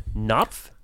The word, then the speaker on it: Knopf: Knopf. I always wondered how you say the name. – They insist that the K has to be pronounced, so it's Knopf.